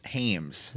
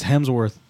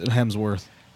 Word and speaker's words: Hemsworth: H-E-M-S. Hames. – Why do you say it like that? It's Hemsworth. – Tomato, tomato. I'm sorry. I'm from a different place of the world than you. – There are movie trailers that say his name out loud, and it's Hemsworth.